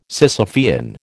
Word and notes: Sisyphean: American English

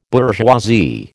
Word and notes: bourgeoisie: American English

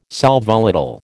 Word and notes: sal volatile: American English

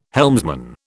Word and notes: helmsman: British English

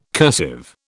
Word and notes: cursive: British English

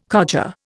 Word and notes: codger: Indian

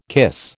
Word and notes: kiss: American English

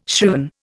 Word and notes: shriven: Indian

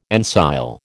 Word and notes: ensile: American English

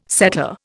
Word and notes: setter: Indian

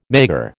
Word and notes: maigre: American English

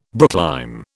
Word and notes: brooklime: British English

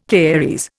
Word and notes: caries: Indian

